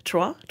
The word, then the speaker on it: Troyes: So how does someone who comes from Troyes, am I saying it right? Troyes? – Yeah, great.